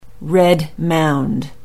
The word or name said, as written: red mound